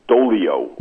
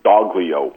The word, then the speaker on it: Doglio: I think it's Dolio, although it's spelled like as though it were Doglio. – Right. – But when she talked to me about it on the phone one day, she left the G silent.